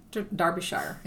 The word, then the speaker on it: Derbyshire: And before the book was actually published, there was an individual who was from the outskirts of London in County Derbyshire, is the way it's pronounced.